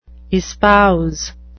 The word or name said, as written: espouse